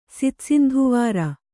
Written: ಸಿತಸಿಂಧುವಾರ

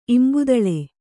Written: ಇಂಬುದಳೆ

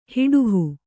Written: ಹಡುಹು

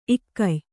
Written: ಈಗ್ಯೆ

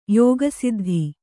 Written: ಯೋಗಸಿದ್ಧಿ

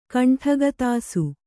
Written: ಕಂಠಗತಾಸು

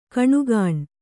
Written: ಘಿಣಿಘಿಣಿ

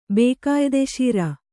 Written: ಬೇಕಾಯಿದೆಶೀರ

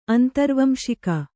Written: ಅಂತರ್ವಂಶಿಕ

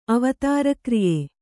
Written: ಅವತಾರಕ್ರಿಯೆ

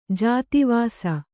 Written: ಜಾತಿವಾಸ

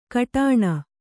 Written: ಕಟಾಣ

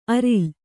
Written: ಅರಿಲ್

